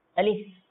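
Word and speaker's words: আলিফ (ألف): আলিফ আলিফ আলিফ। এটাকে যখন আমরা ভেঙে লিখি তো হামজা, লাম, ফা, তো লাস্টে যেটা সেটা ফা উচ্চারণ হবে।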